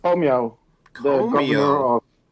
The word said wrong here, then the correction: Cuomo: Oh, Cuomo.